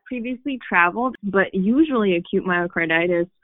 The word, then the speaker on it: myocarditis: Myocarditis? – Yes.